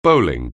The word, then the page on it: bowling: anglais